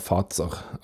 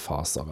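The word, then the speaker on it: Fazer: Eller iallfall det som er et forsøk på sjokolade og logoen var et forsøk på å være fatser, eller faser, eller hva faen du vil kalle det. Iallfall det finske FAZR. E Uttale det som du vil. Jeg pleier å si faser, men eh, Det er jo en Z.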